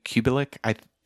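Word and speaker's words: Kubelik: Is it Kublik? – Kublik. Like some people made it three syllables, some people made it more like two syllables, but close enough.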